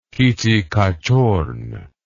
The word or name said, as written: kittikachorn